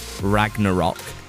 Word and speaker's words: Ragnarok: I've got a screening of the film on Monday night to see Thor Ragnarok.